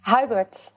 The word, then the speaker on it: Hubert: The wages of a skilled engineer go through the roof in London, and, and I've I've little doubt that uh, Huibert was. Uh, is that how you pronounce his name? Hubert.